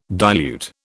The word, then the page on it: dilute: British English